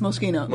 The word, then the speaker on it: Moschino: Moschino. – Oh, it's Moschino.